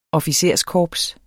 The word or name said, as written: officerskorps